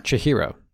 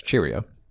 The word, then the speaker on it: Chihiro: Chihiro, which I mispronounced as Cheerio.